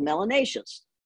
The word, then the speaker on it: melanaceous: Those who have a fair amount of melanin should be called melanaceous, which rhymes with gracious and efficacious.